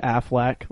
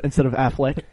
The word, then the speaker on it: Affleck: Affleck, like the insurance. – Affleck. – Instead of Affleck.